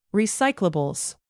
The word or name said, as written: recyclables